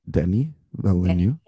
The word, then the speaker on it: Denis Villeneuve: Wait, is it actually is that really how you say his name, Den- Denny Villeneuve? – Yeah. Oh. At least that's how Timothy Chalamet says it.